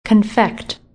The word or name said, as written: Confect